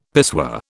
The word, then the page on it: pissoir: British English